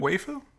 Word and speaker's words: Waifu: He's singing with a woman whose name is spelled W-A-I-F-U. – Wafu? Waifu? – Waifu? – Waifu.